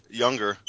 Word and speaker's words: Junger: I'm reading a book called Tribe by Sebastian Younger. I was mispronounced his name. Sebastian, it's spelled Junger with a J, but Sebastian Younger wrote a book called Tribe.